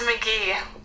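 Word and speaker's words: McGehee: Now, pronounce your, is it McGahey? Is that how you say your last name, or did I fuck that up? – It's McGee. – It's McGee. – It looks like McGee. But it's just McGee. – Spell it for me. So it's like M-C-G-H-E-E something. It's like a lot of E's, right? – It's M-C-G-E-H-E-E. – Can I buy a vowel?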